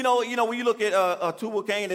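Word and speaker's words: Tubal-cain: Then he had another son, Cain Tubal. Am I saying that right? Say it back. It's Tubal Cain, excuse me.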